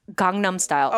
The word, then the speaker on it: Gangnam: Gundam? – Gangnam Style. – Okay. – Most people pronounce it Gangnam Style. – Okay. – That's the American way of saying it.